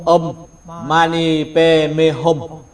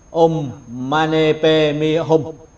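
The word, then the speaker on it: Om Mani Padme Hum: Phát âm của tiếng Tây Tạng là Om Mani Pe Me Hum, Om Mani Pe Me Hum.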